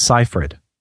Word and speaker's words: Seyfried: Is that how you say that? – Seyfried. No, sorry. You're throwing me off. It's Seyfried.